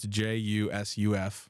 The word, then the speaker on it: Jusuf: And this last one is Jusuf Nurkic, and his name does start with a J. – Yes, it does. – It's just pronounced differently. – Yeah, it's J U S U F.